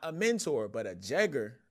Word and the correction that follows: Jegna: right? – Jegna, yes sir. – How you say it? – Jegna. – Jegna, yeah, is the correct term